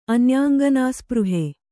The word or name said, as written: ಅನ್ಯಾಂಗನಾಸ್ಪೃಹೆ